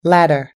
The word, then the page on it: ladder: American English